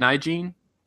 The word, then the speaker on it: Nalgene: Nalgene.